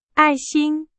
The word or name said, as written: 爱心